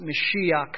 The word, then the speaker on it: Mashiach: And that is Jehovah Mashiach, or some pronounce it Mashiach, coming from the root Mosheah.